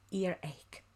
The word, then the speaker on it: earache: We don't say ache or H. Yes, we have ache, ache. So earache. Yes, we pronounce it as K. Yes, earache.